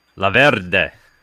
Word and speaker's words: La Verde: La Verde. You gotta roll the R, right? – Yeah, just a little bit.